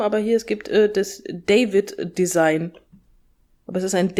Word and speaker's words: Deywid: Aber ja, deswegen, also der wird genauso geschrieben, D-E-Y-W-I-D, David.